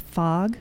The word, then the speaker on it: Fogh: Which is my last name is pronounced Fogh.